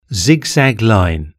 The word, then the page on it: zigzag line: British English